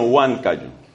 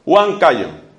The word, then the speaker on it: Huancayo: Que no se dice Juan, sino Huancayo. Huancayo.